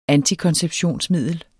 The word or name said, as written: antikonceptionsmiddel